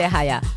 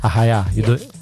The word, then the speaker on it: Arraiá: So we can be referred as Arraial or Arraiá, depends where, or Arraiá, depends which part of Brazil you are, right? – Yeah. – How do you say where you are from? – We say Arraiá. – Arraiá. Like in the south, like in the countryside, we say Arraial.